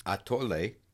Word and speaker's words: atole: I would say it's atole. There's no accent on the E, so it wouldn't be atole. It's atole.